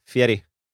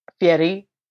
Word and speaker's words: Fieri: Anytime you're on a bed in Omaha and you turn the TV on and it's Guy Fieri. – It's always him. I like that you pronounced it the correct way. – Yeah, Fieri. – Fieri, which took me a long time to learn because I also feel like he doesn't even do a good job of it on Triple D.